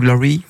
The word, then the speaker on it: Laurie: Je prononce bien Laurie parce qu'aujourd'hui il faut bien prononcer en américain parce que c'est le lancement de la Coupe du monde hein, donc aux États-Unis entre autres.